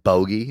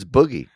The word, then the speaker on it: Boogie: Then it goes over and it's like B O G I E, and like, what, my name's not Bogey, it's Boogie.